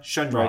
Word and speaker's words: Shundrawn: It's Shun. Like if you think the word Shun or rhyming with sun, Shundrawn.